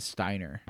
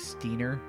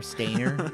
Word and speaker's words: Steiner: But so like my favorite character in the game is Steiner, the knight, right? Is that how you pronounced it? – That's how I pronounced it, yeah. – Yeah, And we both agreed it's definitely probably Steiner. I haven't met anybody who's like Steiner or Stainer or something like that, but both of those sound really awkward and strange.